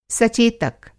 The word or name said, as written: सचेतक